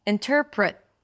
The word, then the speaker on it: interpret: Now, you could just, a T at the end of the word is sometimes just an abrupt stop of air. Interpret.